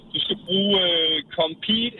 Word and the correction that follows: Compeed: eller Compeed. Jeg kan ikke huske præcis udtalen. – Compeed, Compeed.